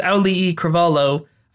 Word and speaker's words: Auli'i Cravalho: a bunch of the paparazzi essentially like couldn't pronounce her name correctly or were just calling her Moana. And she was like, no, my name is Auli'i Cravalho. I'm not Moana. That's the character. And just shutting them down. And that's how I know how to say her name.